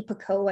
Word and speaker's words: Picoult: I am a big fan of, I think you say her name, Jodi Picoult. I think it's French. Picoult, it's spelled P-I-C-O-U-L-T.